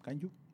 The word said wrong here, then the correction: kaiju: Kaiju. Kaiju. Perdón, siempre lo digo mal.